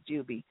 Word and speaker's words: Jubii: It's Jubilee, but it is spelled the way I wanted it, J-U-B-I-I, which is Juby.